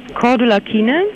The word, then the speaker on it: Cordula: Nee, C wie Cäsar. – Ja, weiter. – C-O-R. – Rudolf. Dann weiter. Dora. – Dora. U-L-A. – U-L-A. Mhm. Hab ich. Mein Name ist Cordula. Weiter. – K-I-E-N-E.